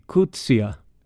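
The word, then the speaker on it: Coetzee: Nu har två lyssnare skickat information om det faktiska uttalet och tydligen ska den första stavelsen uttalas ungefär som i engelskans book, medan den sista stavelsen ska uttalas som sista stavelsen i idé. Alltså bör det bli kutsia eller liknande.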